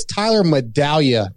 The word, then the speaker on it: Medaglia: Just to correct Steve, and I'm gonna correct you guys because you probably don't know, but Steve should know, it's Tyler Medaglia. The G is silent.